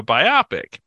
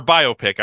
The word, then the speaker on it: biopic: which is you said this film is kind of a biopic, or biopic. I'm sorry. I had a professor in graduate school, a film professor who always pronounced that biopic. So in my head, I have that, even though it makes no sense to call it a biopic.